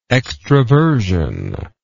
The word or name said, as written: extrovertively